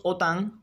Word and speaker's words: OTAN: Así, lo normal es pronunciar COVID, no COVID, como mismo decimos OTAN y no OTAN.